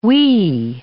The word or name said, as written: wee